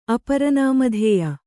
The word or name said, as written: ಅಪರನಾಮಧೇಯ